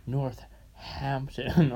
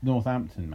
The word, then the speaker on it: Northampton: It's Northampton, mate. – You're just like, they don't even pronounce the H of it. – Leicester Square. Because there is no H. There's one H.